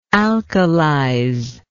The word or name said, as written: alkalizer